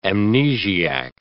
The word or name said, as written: amnesiac